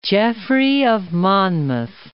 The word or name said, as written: Geoffrey of Monmouth